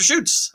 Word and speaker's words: Schutz: Dr. Schutz, a Minnesota native, hosts and produces a highly ranked show called Paranormal Encounters podcast series heard on various platforms. So, Dr. Schutz, am I pronouncing that right? – Yes, that is correct.